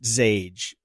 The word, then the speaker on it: Xage: I think it's pronounced Zage. XAGE Security.